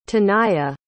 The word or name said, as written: taniyah